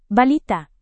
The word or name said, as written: balita